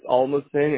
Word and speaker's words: Almasan: So it's Alma's Fan, and it's spelled A-L-M-A-S-A-N. – I am glad you spelled that. A-L-M-A-S-A-N.